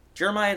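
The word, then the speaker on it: Jeremiad: Jeremiah, it's the pronunciation of that, right? Yes, it is.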